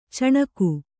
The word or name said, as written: ಚಿಣಿಗಿ